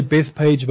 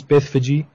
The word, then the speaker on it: Bethphage: Matthew 21 verse 1 to 5, Matthew writes, now when they drew near Jerusalem and came to Bethphage, don't think my pronunciation is wrong, we say Bethpage but it actually reads Bethphage.